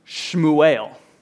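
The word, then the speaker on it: Shmuel (שמואל): In Hebrew, the language that Hannah spoke and the language that the Old Testament is written in, the name Samuel is actually pronounced Shmuel.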